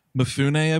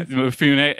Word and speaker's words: Mifune: Mifune, I believe. – Yeah, Mifune.